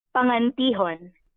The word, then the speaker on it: Pangantihon: So my last name is pronounced Pangantihon.